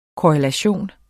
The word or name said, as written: korrelation